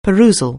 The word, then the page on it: perusal: anglais